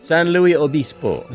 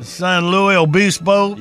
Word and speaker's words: San Luis Obispo: San Luis Obispo. – San Luis Obispo.